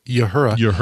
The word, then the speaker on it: Uhura: We mentioned earlier that Nichelle Nichols, Uhura, or as some people pronounce it, Yuhura, passed away this last weekend.